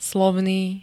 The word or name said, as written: slovný